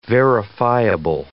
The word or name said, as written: verifiable